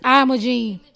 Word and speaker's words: Imogen: Her name is Imogen. – Hey, I'm gonna just go with the internet. The computer knows what how to okay. – say. – Okay, Imogen.